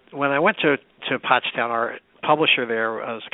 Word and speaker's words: Pottstown: Well, the Mercury of Pottstown, am I pronouncing that correctly, Pennsylvania? – Pottstown, yeah.